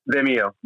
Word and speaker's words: Vimeo: Um, definitely seek them out because, like I always say, the money you're spending, especially if you go directly with Todd Tranchel the Vin, Vin, what is it? Vin, Vimeo, Vimeo on demand.